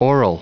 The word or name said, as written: aural